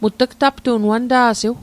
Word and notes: aptu'n: Mi'gmaq

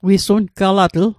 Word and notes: wisungalatl: Mi'gmaq

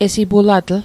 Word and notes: esipulatl: Mi'gmaq

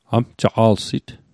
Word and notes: amjaqa'lsit: Mi'gmaq

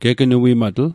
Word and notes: gegnuimatl: Mi'gmaq

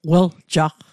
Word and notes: weljaqq: Mi'gmaq

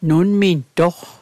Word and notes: nunmintoq: Mi'gmaq

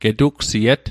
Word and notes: getugsiet: Mi'gmaq

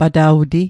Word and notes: pata'wti: Mi'gmaq

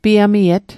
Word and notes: piamiet: Mi'gmaq

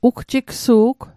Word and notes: ugjigsu'g: Mi'gmaq

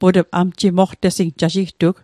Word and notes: amjimoqtesing: Mi'gmaq

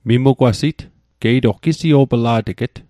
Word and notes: mimugwasit: Mi'gmaq

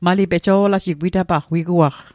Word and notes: pejo'laji: Mi'gmaq

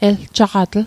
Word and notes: eljaqatl: Mi'gmaq

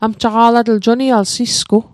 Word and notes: amjaqa'latl: Mi'gmaq

